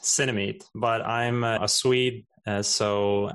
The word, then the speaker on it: Cinemeet: So the app is called Cinemeet. It's spelled like cinema and meeting somebody. So Cinemeet.